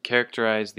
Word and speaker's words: characterize: And characterize, the emphasis is on the first syllable. Ke, characterize.